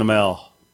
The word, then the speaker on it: Amell: They're saying you're saying it wrong. It's Stephen Amell. – I say Amell.